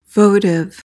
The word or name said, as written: votive